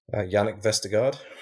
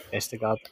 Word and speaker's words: Vestergaard: Yannick Vestergaard. – Vestergaard.